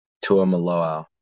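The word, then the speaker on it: Tuimoloau: When Ohio State came back, drove down the field, and then JT, Tua Malaya. I never know how to pronounce it. JT Tua? – Tua Maloau.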